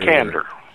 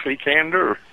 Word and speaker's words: Cekander: Cekander. – Cekander! I was so far away from that. – Yeah, just the way it looks, Cekander.